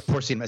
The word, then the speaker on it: porcinum: Porcinum? I think, I don't know. Is that the right to pronounce it? Anyway. – Yeah, porcinum is how I would say it.